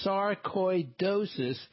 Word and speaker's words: sarcoidosis: It's pronounced sarcoidosis.